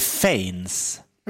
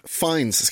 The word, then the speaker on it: Fiennes: Och skådespelaren Ralph stavas Fiennes, men jag tror man säger Fiennes. – Ralph Fiennes. Vad säger Nyhets Jonas? – Ja, precis. Ralph Fiennes ska uttalas trots att det inte stavas ens i närheten på det sättet.